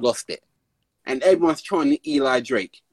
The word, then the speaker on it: Eli Drake: So basically, there's this wrestler called Eli Drake. That's how he pronounces his name, oh. like that.